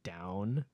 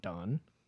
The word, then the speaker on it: DVWN: His name's Down. He signed under Zico's label. It's DVWN. – Mm-mm. – It's pronounced down, not Don.